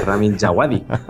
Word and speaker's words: Ramin Djawadi: Me tengo que ir a un traductor de internet para que me diga que se llama Ramin Djawadi.